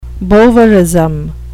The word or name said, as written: bovarism